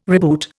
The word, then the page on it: reboot: Indian